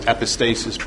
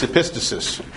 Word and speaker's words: epistasis: A lot of people mispronounce it as epistasis, but it's epistasis.